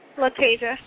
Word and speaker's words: Latasia: Latasia.